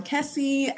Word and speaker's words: Kessie: You can find me at Ama Kessie at Twitter or on Instagram. – And how do you spell that? – A-M-A, and then the last name is K-E-S-S-I-E.